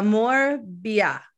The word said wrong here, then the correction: Morbier: No. Yeah. No. La Morbier. La Morbier. Yes. Morbier.